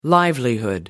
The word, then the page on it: livelihood: American English